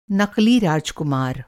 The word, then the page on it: नक़ली: نقلی